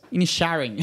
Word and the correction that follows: Inisherin: É, Inisherin.